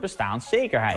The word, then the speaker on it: bestaanszekerheid: Maar wat is nu de goede uitspraak? Nou, Het woord staat al 40 jaar in de grondwet en vroeger sprak iedereen het min of meer op dezelfde manier uit. Gewoon zoals de dikke vandalen ook aangeeft met de klemtoon op bestaan. Het is bestaanszekerheid. Eigenlijk helemaal zoals we dat met vergelijkbare woorden doen.